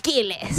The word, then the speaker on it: Quiles: Elena Rose y Justin Quiles. ¿Cómo se pronuncia? Quiles. – Justin Quiles. Así, a los salvadoreños, al Quiles.